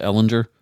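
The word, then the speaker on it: Ehlinger: You said they're 3-3-1? – I believe so. Yeah, that's 3-3-1. – And the other quarterback is, I think you pronounce it, Ellinger. – Yep. – Ellinger? – 2021 sixth round pick.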